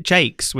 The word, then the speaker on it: Jacques: I pronounce it Jakes, which Jakes. Was how my grandmother pronounced it and how Hattie Jakes used to pronounce it.